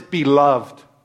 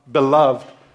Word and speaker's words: beloved: I want to look at one particular word in, this, in these two verses, and the word is beloved. Beloved. Some people, uh, I think the British say beloved, but that's three syllables. I'm going with two, man.